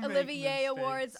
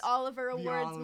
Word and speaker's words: Olivier: I pronounced Olivier Awards, Oliver Awards my whole life.